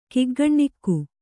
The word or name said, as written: ಕಿಗ್ಗಣ್ಣಿಕ್ಕು